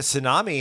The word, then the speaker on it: tsunami: It's spelt T-S-U-N-A-M-I, but the T is silent. Tsunami. It's a Japanese word, which I think means big wave or something like that.